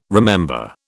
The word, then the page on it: remember: British English